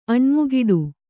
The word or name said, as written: ಅಣ್ಮುಗಿಡು